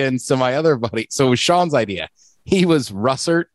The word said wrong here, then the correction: russet: I always say that wrong. Russert. Russert potatoes. – Russet. – Russet. Russet. Yeah.